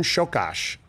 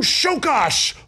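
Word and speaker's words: Csokas: It's Martone Shokash. Learn how to pronounce it. And I was like, I will learn how to pronounce this. Martone Shokash.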